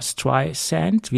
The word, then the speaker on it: Streisand: paar Monaten mit Z ausgesprochen und nicht mit S wie Sand. Äh, das heißt ja Barbara Streisand, der Sand am Strand.